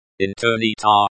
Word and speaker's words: Интернета: Интернета, the stress is on the third syllable, ter.